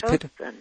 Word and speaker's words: Pitocin: Why is there reason to be concerned about the use of labor-inducing drugs like Pitocin, or is that how it's pronounced? – Pitocin. It's called Pitocin.